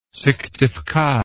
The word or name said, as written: syktyvkar